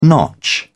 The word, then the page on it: notch: British English